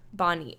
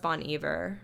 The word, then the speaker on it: Bon Iver: Um, ben Howard, The Head and the Heart, um, Judah and the Lion, The Paper Kites, James Bay, Bonnie. I think it's pronounced Bon Iver. I'm pretty sure.